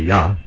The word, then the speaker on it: Yah: If you look in your Strong's Concordance or Hebrew lexicon, 3050, and then the one for Jehovah, of course it's not pronounced with a J anyway, it should be Yah, Yahovah, and Y-A-H, we just read there.